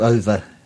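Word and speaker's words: Ove: A Man Called Olaf. – A Man Called Over.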